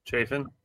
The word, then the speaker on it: Chafin: I took Andrew Chaffin. Chaffin? Am I saying his name wrong? Chafin.